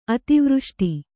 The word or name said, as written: ಅತಿವೃಷ್ಟಿ